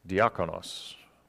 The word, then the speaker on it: diakonos: Now the word deacon is interesting because it comes from the Greek word diakonos. Did I say that right, Pastor? There we go.